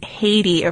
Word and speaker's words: Hedy: And she couldn't say Hedwig, so she called herself Hedy, which was actually pronounced Haiti over there. We're going to go with the more anglicized. – Yeah, the anglicized, better known version of her name, Hedy.